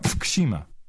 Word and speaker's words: Fukushima: We're not pronouncing it right. Fukushima Daiichi. You're not pronouncing it right. Fukushima. Try it. Fukushima.